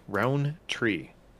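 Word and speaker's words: Rountree: No D, Roundtree.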